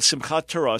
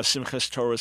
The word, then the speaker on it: Simchat Torah: And again, it reminds us, it's called Simchat Torah, Simchas Torah is another pronunciation.